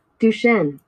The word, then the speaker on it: Duchenne: Duchenne.